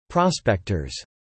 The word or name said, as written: prospectors